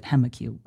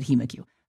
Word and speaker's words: HemoCue: He pronounces it HemaQ. I think in the United States, we pronounce it HemaQ.